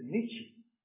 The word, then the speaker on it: Nietzsche: 哎，我们换换国外的，德国有一个人叫做尼采，我到神学院读书才知道他的名字用德文的发音是 Nietzsche。我从中文来念，我想的是尼采，他是尼采，不是尼采。